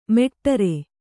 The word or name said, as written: ಮೇಢ್ರ